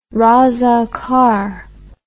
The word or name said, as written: razakar